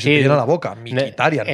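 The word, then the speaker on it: Mkhitaryan: Dice, me parece que Tatitov, eh, que cómo se pronuncia Mikitarian, que desde el Borussia no sabe, es así, ¿no? Mikitarian. Sí, yo creo que es Mikitarian.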